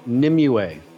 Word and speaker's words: Nimue: Milita Jovovich as Nemu, I couldn't get the name right, even in the movie. Nemu, the Blood Queen. Ian McShane as Professor Broom. – It's Nimue.